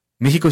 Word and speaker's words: México: México se pronuncia México. Y la historia de por qué se pronuncia México es de verdad muy bella, porque tiene que ver con la evolución del sh.